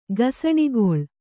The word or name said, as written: ಗೋಸಣೆಗಳೆ